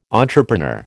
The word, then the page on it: entrepreneur: American English